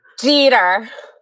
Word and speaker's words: Jeter: How do you pronounce this name? – Jeter. – That is correct. Ding, ding, ding.